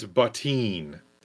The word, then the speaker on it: Bottin: It's Bottin is how you pronounce his last name. Rob Bottin, according to IMDb trivia.